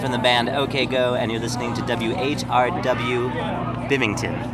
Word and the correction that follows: Binghamton: Bimington. How do you say it? – Binghamton. – Binghamton. – Yeah. – Oh, I spelled it wrong. How do you spell it? – B-I-N-G. – Oh. – H-A-M.